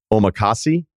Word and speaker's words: omakase: The man was trying to watch the Sixers-Lakers game on his phone while trying to enjoy some amazing omakase. Is that right pronunciation? I'm pretty basic. – I believe it.